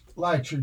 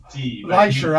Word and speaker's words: Licher: And Lanterna with Bruce Licher. Did I pronounce that right? – Yeah, okay. Licher. – It's no, Licher, I'm sorry, but what about Lanterna? – Lanterna, yes.